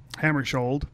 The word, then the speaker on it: Hammarskjöld: Hammarskjöld. – There's a K in it. – Yeah. – There's a T in your last name. You don't pronounce that either.